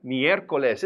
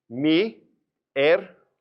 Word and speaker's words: miércoles: But just sound it out. Remember, get those vowels out of your toolbox. Miércoles. Miércoles. And look where that accent is. That's where you stress it. Miércoles. And miércoles was the day honoring Mercury, the messenger of the gods.